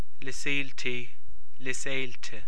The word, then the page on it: Lios Éilte: Irish